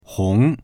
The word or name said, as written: hong2